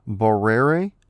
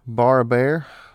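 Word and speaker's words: Barrère: You have Paul, and I'm probably going to butcher this, it's Barrere, B-A-R-R-E-R-E, Barrere. Bar a bear, I don't know.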